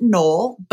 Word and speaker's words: Knoll: The last name is spelled K-N-O-L-L. And many people will pronounce it Knoll, but you actually pronounce the hard K. It's Knoll. And it's one of those things, right?